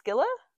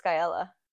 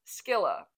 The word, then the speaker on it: Scylla: Sorry, is that how you say it? Scylla? – What do you think? – I always pronounce it Skyella. – There's no E. It's S-C-Y-L-L-A. – I know, but in my brain, Scylla. It was Skyella. – Because the Y in Latin does not make an O.